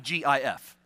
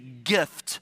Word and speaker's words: GIF: There's the one theory, which is the one position, which is my position, that it is pronounced with the hard G, like when you say gift or groceries or great.